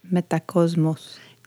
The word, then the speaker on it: Metacosmos: I wonder if it's pronounced Metacosmos. – It definitely is.